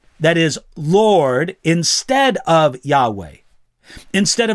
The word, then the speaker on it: Yahweh: For some years, those letters YHWH were mistakenly pronounced as Jehovah instead of Yahweh or Yahweh.